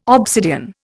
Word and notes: obsidian: Indian

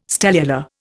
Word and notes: stellular: Indian

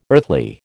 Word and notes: earthly: American English

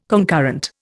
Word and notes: concurrent: Indian

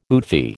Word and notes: oofy: American English

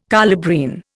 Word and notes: colubrine: Indian

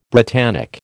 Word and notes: Britannic: American English

